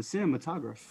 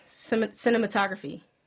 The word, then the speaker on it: cinematograph: They were French inventors and manufacturers of photographic equipment who devised the motion picture camera and projector called the cinematograph. Is that how I pronounce that? – Cinematography. Cinematographer? – Cinematograph. – Cinematograph. – It's not cinematography, it's cinematograph.